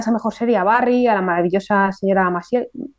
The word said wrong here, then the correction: Maisel: eh, lo he dicho mal. Maisel,